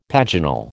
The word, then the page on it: paginal: American English